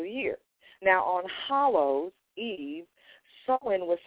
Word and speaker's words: Samhain: Now, the celebration of Hallow's Eve honored the pagan of the Celtic god pronounced Samhain. It's spelled S-A-M as in Mary, H as in Harry, A-I-N. Samhain, the Lord of the Dead.